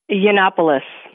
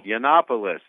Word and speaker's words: Yiannopoulos: Yiannopoulos. – Yiannopoulos, okay.